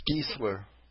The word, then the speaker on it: Giesler: And how am I pronouncing Hermann Gessler? – It's Giesler. – Giesler, thank you. – If the I is first, it's E, and if the E is first, it's I.